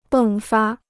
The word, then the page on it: 迸发: bèng fā